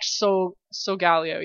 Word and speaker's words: Solgaleo: And then I really liked Solgaleo. Yeah, that's how you say it. – Yeah. – I like Lunala too, nope. But Solgaleo, yeah, he's definitely why I picked Sun.